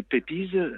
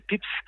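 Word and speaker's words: Pepys: Là, moi, en le refermant, je me suis jeté sur le journal de Samuel Pépiz, qui paraît-il, on doit prononcer Samuel Pips,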